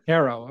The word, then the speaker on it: HARO: He's the founder of, I don't know how you're supposed to pronounce this, but H-A-R-O, Harrow, Help a Reporter Out. – Harrow. – Okay, thanks.